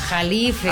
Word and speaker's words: Jalife: Bueno, entonces dígame usted, ¿quién es Alfredo Califa? – Jalife. – Ah, yo soy Califa. – Oye, Jalife, pero no sé si es Jalif. Ah. Jalife, pero se escribe Jalife.